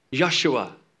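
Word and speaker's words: Yeshua (ישוע): In Hebrew, it's pronounced Yeshua or Yehoshua. We might pronounce it Joshua.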